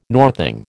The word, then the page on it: northing: American English